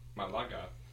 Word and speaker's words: Malaga: M-A-L-A-G-A. Malaga. Malaga. – That sounds pretty cool. – It is cool. It is an ambidextrous sling bag. So by the way, I just looked it up. Malaga is Polynesian for candy bar.